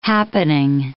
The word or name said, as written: happening